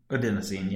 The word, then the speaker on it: adenosine: How do you pronounce it? Adenosine? – Adenosine, yep. Yep.